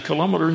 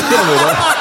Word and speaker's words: kilometre: I've just wasted about an hour because you're still saying kilometre instead of kilometre.